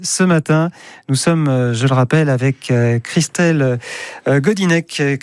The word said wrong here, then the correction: Gonidec: Christelle qui est la responsable, Gonidec, pardon, j'ai écorché votre nom d'ailleurs.